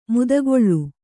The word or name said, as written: ಮದಕಲ